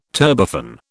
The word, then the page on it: turbofan: British English